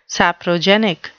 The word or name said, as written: saprogenic